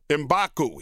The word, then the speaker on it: M'Baku: M'Baku.